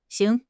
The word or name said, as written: xing2